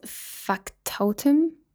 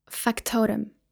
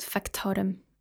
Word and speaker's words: Factotum: Another excerpt from one of Bukowski's novel called Factotum. That's totally not how you say it. Wait. Factotum. I googled it. That's how you say it. Factotum.